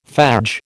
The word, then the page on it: fadge: American English